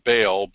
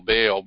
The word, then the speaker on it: Baal: or we call it Baal, B-A-A-L, Baal, but it's actually pronounced in the Hebrew as Baal.